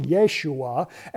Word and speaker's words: Yeshua: Jesus is from the Greek version of the Hebrew name Joshua. In fact, it would have been pronounced Yeshua, and that literally means God saves.